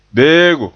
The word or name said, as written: beego